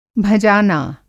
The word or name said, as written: भँजाना